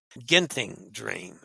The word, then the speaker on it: Genting Dream: I think that's properly pronounced Genting Dream, if I remember from Dennis's lessons.